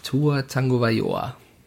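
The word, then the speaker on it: Tua Tagovailoa: Tua Tango Bayoa. Ja, also für alle da draußen, die jetzt sagen, wieso Tango und nicht Tago, äh, Hawaiianer, da gibt's versteckte Ns von ja.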